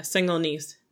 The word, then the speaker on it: Senegalese: Is that how you say it right? Senegalese. She's from Senegal. How do you say that? – Yeah, Senegalese.